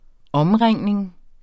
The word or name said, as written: omringning